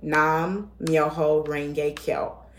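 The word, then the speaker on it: Nam-myoho-renge-kyo: I said, but this is how you say it. Nam-myoho Renge Kyo.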